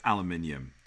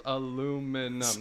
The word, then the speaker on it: aluminium: Aluminium. That's 5 syllables. Aluminum. That's 4. Yep.